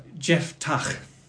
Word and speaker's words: Jephthah: How do you pronounce it properly? – It's Jephthah.